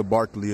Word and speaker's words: Barkley: Yeah, Isaiah Barkley is my name. Isaiah is just like it's spelled in the Bible, six letters. And Barkley, like Charles Barkley.